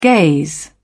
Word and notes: gaze: British English

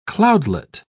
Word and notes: cloudlet: British English

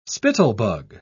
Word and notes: spittlebug: British English

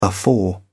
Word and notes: afore: British English

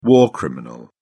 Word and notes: war criminal: British English

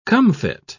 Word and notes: comfit: British English

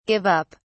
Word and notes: give up: British English

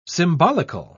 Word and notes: symbolical: British English

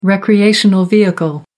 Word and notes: recreational vehicle: American English

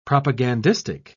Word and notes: propagandistic: British English

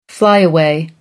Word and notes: flyaway: American English

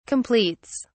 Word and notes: completes: British English

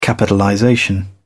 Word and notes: capitalization: British English

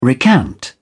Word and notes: recant: British English

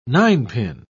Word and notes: ninepin: British English

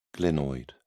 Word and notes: glenoid: British English

